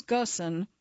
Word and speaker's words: Ghosn: As the jet-setting chairman of three car companies, Nissan, Mitsubishi, and Renault, Carl Ghosn, the Arabic pronunciation is Ghosn, had homes in Rio, Beirut, Paris, and Amsterdam.